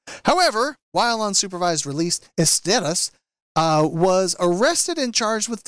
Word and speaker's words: Esteras: And it's Esteras. Is that, do you think I've pronounced that right? – Esteras.